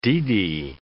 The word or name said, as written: deedy